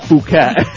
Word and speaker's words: Phuket: It is from, Phuket, Thailand. It's actually pronounced Phuket. Like Fred.